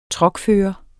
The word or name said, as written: truckfører